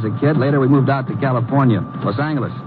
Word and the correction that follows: Los Angeles: Correction. Los Angeles. Oh? Yeah, soft G.